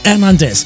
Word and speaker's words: Hernandez: Aus diesem Grunde Patrick Hernandez, denn die Franzosen können da kein H aussprechen.